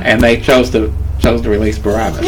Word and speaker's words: Barabbas: A, Barabbas. Barabbas. I always freaking mispronounce this. Okay. C, Barnabas. C, Bartholomew. Bartholomew. D, Barak. – It's Barabbas.